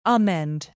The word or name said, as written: amend